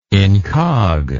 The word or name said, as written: incog